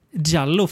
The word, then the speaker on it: Djallo: Han har ju faktiskt skrivit en, eh, en, en svensk giallo som heter Jallo, fast D-J-A-L-L-O. Eh, ja. Han har skrivit det som det uttalas.